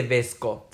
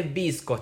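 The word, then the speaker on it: VSCO: no se dice Vesco, se dice Visco.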